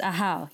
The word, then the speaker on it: Ahau: Firstly, did I pronounce it right? And did I describe it correctly? – Yes, Ahau. Yes, that's right.